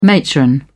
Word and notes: matron: British English

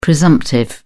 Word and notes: presumptive: British English

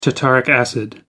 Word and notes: tartaric acid: American English

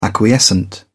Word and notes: acquiescent: British English

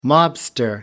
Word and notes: mobster: American English

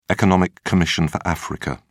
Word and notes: economic commission for africa: British English

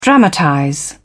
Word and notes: dramatize: British English

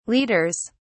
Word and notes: leaders: British English

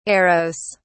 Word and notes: eros: British English

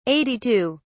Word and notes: 82: British English

